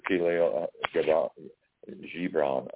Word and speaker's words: Khalil Gibran: The Khalil Gibran is actually how it's pronounced.